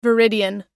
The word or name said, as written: viridian